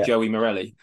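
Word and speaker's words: João Morelli: Yeah, so I'm really glad he's recovering, and obviously that then gave the chance for how'd you pronounce his name again? Is it yeah, Joey Morelli. – Joey Morelli? There we go. We're gonna go with Joe Morelli.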